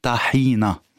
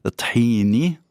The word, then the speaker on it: tahina: Alors je vais me remettre à la langue arabe écrite, on dit tahina, donc c'est tahina. Après, on peut dire en libanais avec l'accent tahini, tahina, donc tout se dit, mais si on écrit le mot tahina en arabe, c'est tahina, et c'est comme ça qu'on devrait le prononcer tahina, avec un A.